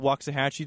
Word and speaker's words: Waxahachie: I think I got Waxahachie down.